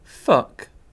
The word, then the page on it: fuck: British English